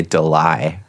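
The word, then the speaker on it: Delisle: It's not Gray Delise Griffin. It's Gray Delai Griffin. – That's hot. Oh. – So it's like like Isle, you know?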